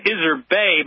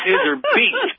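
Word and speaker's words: Iserbyt: I've been pronouncing her name as Iserbay, but it's Iserbeet.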